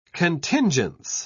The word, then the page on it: contingence: British English